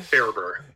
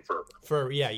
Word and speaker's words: Faerber: Yes, I think some of us are more trying to adhere more to our German roots and have started calling it Faerber. – Yeah. – And that's how it's spelled. But I grew up saying Faerber.